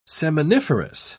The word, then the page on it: seminiferous: British English